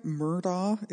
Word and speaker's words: Murdaugh: And yeah, it, am I pronouncing that right? Murdaugh? It's, I mean, people say it's like murder. Like it sounds like murder.